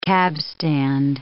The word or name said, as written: cabstand